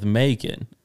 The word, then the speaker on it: Macon: Bacon rhymes with Macon, and Macon, Georgia is close to where the Walking Dead comics take place.